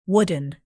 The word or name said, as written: wooden